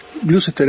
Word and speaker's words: Gloucester: Le digo, ya tengo el bolso pronto, nada, ahí arranqué, me fui para Gloucester, era, ¿no? – Gloucester, sí, nunca lo dije bien.